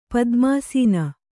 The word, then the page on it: ಪದ್ಮಾಸೀನ: padmāsīna